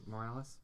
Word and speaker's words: Morales: Did I say that right? Morales? – Yeah.